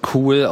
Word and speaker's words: Cuil: Punkt .com, schreibt sie irgendwie C-U-I-L. Angeblich soll man das cool aussprechen. Aha. Ich weiß ja nicht genau, was sie sich dabei gedacht haben. Also erstens klingt für mich C-U-I-L einfach nicht wie cool.